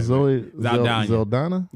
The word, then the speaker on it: Zoe Saldana: Zoe Saldana.